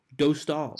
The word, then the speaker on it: Dostal: I have a Czech friend on my roller hockey team who I asked him for pronunciation advice, and apparently it's Lukas Dostal. – Dostal. – So, Dostal. Yeah.